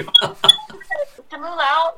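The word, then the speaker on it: Simu Liu: Simu Lau, like, am I saying that right? – Right? Yes.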